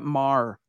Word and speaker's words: Maher: Yeah, they're at this stage, and I got to assume that you pronounce Brett Maher Maher, just like the comedian Bill Maher, yeah.